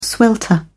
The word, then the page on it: swelter: British English